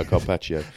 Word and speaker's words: carpaccio: In Capaccio. I don't know if that's the correct pronunciation. isn't that raw meat? – You've got other strengths, yeah, Carpaccio.